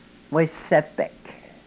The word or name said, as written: wesepek